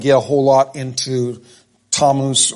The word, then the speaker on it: Tammuz: some pronounce this Timaeus, others pronounce it Tammuz. I prefer to say Timaeus, but actually the correct pronunciation is Tammuz.